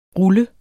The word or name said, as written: ruller